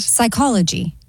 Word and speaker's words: psychology: Psychology. – Correct. The P is silent in psychology.